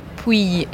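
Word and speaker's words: Pouille: I think Puy. Did I say that right? – Puy. It's more of a... Puy. More of... Imagine there's a Y in the L's.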